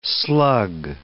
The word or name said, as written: slug